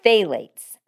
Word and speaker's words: phthalates: It's super funky. It's phthalates. Phthalates, but here's how it's spelled. P-H-T-H-A-L-A-T-E-S. So phthalates have been linked to allergies, asthma, infertility, hormone imbalance, abnormal reproductive development.